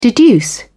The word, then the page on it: deduce: British English